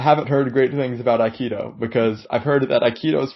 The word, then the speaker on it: Aikido: Is it pronounced Aikido? – Aikido President President of the Aikido Club at UNC Chapel Hill.